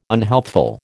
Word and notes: unhealthful: American English

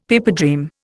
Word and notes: pipedream: Indian

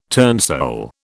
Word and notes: turnsole: British English

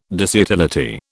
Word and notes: disutility: British English

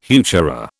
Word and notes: heuchera: British English

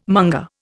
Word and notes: monger: Indian

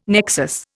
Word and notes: nexus: Indian

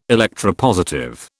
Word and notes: electropositive: British English

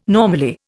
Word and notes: normally: Indian

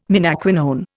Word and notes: menaquinone: Indian

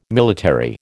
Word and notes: military: American English

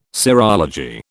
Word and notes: serology: British English

